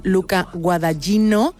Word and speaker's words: Luca Guadagnino: por el director italiano Luca Guadagnino.